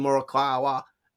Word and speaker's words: Morikawa: He was a teammate of Scotty Scheffler, Colin Morikawa. That's a good pronunciation.